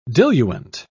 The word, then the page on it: diluent: British English